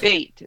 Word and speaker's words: bayt: alif and the B A Y T, the bayat. – Bait. Bait. I, I, say aleph and bait.